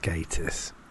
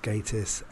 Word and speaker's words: Gatiss: But in actual fact, it is pronounced Gatiss, from the man himself, Mark Gatiss, on Twitter, at The Daily Crumb. It is pronounced Gatiss and no other way. Just think gay.